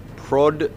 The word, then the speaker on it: Podgorica: Podgorica.